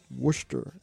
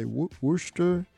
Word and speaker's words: Worcester: Worcester, as they say. Worcester. It's spelled Worcester, but they pronounce it Worcester something.